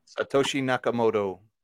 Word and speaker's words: Satoshi Nakamoto: Satoshi Nakamoto.